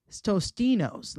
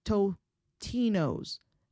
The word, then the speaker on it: Totino's: The popular pizza rolls from the brand Tostino's is not Tostino's. Let me explain this. Check it out. It's Totino's. It's not Tostino's. People put an S in there. It's actually spelled T-O-T-I-N-O. It's like Tutino.